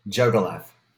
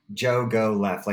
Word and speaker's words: Jogolev: Jogolev. – Jogo? – Yeah. Jogo left. Like if you're telling a guy named Jogo left, take a left.